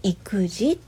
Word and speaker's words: 意気地: これで「育児」って読むんですよね。